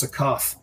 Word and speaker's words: Akoth: And Kelleth will explain to you that's his bosun, that's a Koth. He's a dust goblin from the wastes. It's not a good idea to irritate him. – Did you say his name was Akost? – Akoth. Akoth. – Akoth. – Yeah. A-K-A-T-H.